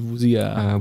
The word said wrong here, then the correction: Wuxia: Äh, Wuxia, Wuxia, ja. oder würdest du es aussprechen? – Genau. Wuxia, genau.